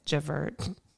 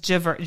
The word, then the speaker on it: Javert: It's Javert. Probably not how you say it. – John. – It's Javert. J-A-V-E-R-T.